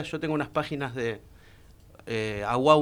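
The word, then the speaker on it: Awá: Y los Aguá, en realidad es con acento en la última, que son una tribu de pueblos originarios que viven en Ecuador y que están en peligro de extinción, ¿no?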